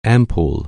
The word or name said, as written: ampoule